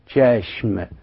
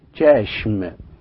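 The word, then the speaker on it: چشم: يعني على سبيل المثال عندهم كلمة العين، العين في الفارسية معناها تشاشم تشاشم، وجيم تحتها ثلاث نقطات وشين وميم، فلو حاولنا ان احنا نقراها لا بتقول ايه تشاشم تشاشم. الجيم المثلثة نطقت تماما مثل حرفي السي والاتش في اللغة الإنجليزية تشاشم.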